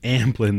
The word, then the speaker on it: Amblin: Amblin, not Ambien. That's a whole. – I was like, that's a drug for sleeping, I believe. Yeah, that's a much sleepier production company. Amblin, right.